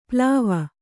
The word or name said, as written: ಪ್ಲಾವ